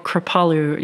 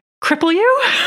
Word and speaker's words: Kripalu: So I teach something called Kripalu yoga and I always make this really lame joke about it because Kripalu is spelled K R I P A L U and so many people when they look at it they're like, cripple you? like, cripple you yoga.